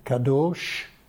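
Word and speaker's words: kadosh (קדוש): Kadosh, with the that bit in there, kadosh. The people are often called holy, but if you notice, there's a consonant missing. It's still kadosh, it's a long O, but it hasn't got the vav in it.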